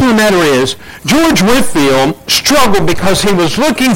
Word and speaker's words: Whitefield: It is spelled as Whitefield, but he went by the word Whitefield.